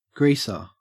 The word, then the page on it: greaser: Australian English